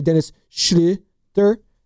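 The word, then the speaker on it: Dennis Schröder: He's got the Undlot is the two little dots above the O, so it's the U pronunciation. So you have to say Dennis Schroeder.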